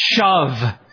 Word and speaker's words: shuv: The Hebrew word to turn or repent is pronounced shuv.